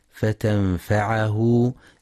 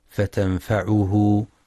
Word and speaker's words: فتنفعه: وينتبه القارئ برواية حفص في كلمة فتنفعه بفتح العين، فلا يقرأ فتنفعه بضم العين.